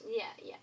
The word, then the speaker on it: Gris: Like, Gris is another one. I don't know how to pronounce it, but... – Gris.